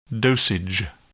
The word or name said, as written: dosage